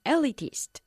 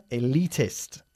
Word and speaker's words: elitist: ¿Cómo se dice elitista? – Elitist. – ¿Y cómo se pronuncia? Elitist. – Elitist. – Enfatizando la segunda sílaba.